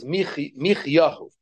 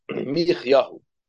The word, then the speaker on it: Michyahu: His name, not Micha, but his name was Michyahu. Michyahu. That's his name. That's how you pronounce it, according to the Nekudos.